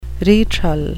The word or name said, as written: retral